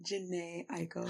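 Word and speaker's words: Jhené Aiko: We just listened to Psilocybin by Janae Aiko. – You said her name right.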